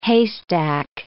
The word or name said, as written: haystack